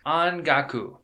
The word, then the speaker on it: ongaku: It is ongaku. That is spelt O-N-G-A-K-U, ongaku.